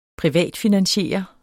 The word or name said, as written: privatfinansiere